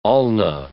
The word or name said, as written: ulna